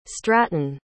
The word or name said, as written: stratton